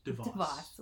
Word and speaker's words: DeVos: But I know Betsy Dubois, is that how you say her name? – Betsy DeVoe. DeVos, whatever. I gave her a better name. – Or DeVos, not Dubois.